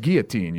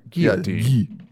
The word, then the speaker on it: guillotine: Just like guillotine, you don't pronounce the L's, technically. – Guillotine.